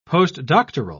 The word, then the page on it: postdoctoral: British English